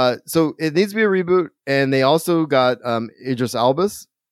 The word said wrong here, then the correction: Idris Alba: Idris Alba, right?